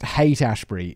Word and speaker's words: Haight-Ashbury: Apparently, it's pronounced Haight-Ashbury. It's spelled like Haught. Haught.